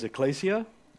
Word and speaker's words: ekklesia: The Bible original word for church is ecclesia. I did not put it on the screen, so I'll spell it for you. E-K-K-L-E-S-I-A.